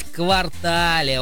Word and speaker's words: квартале: Да, существует мнение, Олечка, что данное слово в значении «часть города» произносится с акцентом на последний слог, а в значении «четверть года» на первую гласную «а». Однако это не так. В словарях зафиксирован лишь один верный вариант произношения этого существительного. И он с ударением на последний слог, поэтому правильно говорить «квартале», Олечка.